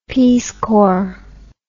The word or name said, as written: Peace Corps